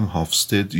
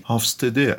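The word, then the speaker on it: Hofstede: اگر اشتباه نکنم هافستد یا با تعبیر و تلفظ دیگه ای هافستده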